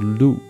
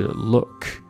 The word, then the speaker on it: look: 包括这个看 l o o k，它也不读 look 啊，它读的是 look look 啊。发音的要领就是你的嘴唇张开大概一个小指。